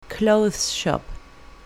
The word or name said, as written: clothes shop